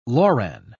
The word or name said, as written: loran